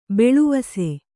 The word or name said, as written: ಭುಲ್ಲವಿಸು